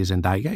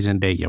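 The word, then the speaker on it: Zendaya: Μετά όμως έρχεται τώρα η δεύτερη ταινία, γνωρίζει τον χαρακτήρα που υποδίεται η Ζεντάγια, ή Ζεντέγια όπως την προφέρουν κανονικά, αλλά εμείς θα την πούμε Ζεντάγια για τις ανάγκες αυτού του podcast.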